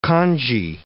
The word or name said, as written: congee